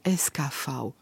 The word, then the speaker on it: SKV: Et en fait, on le prononce, même en Transylvanie, même quand on parle roumain ou hongrois, on le prononce à l'allemande. Le V en allemand se dit V, et donc on dit SKV.